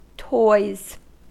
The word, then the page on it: toys: American English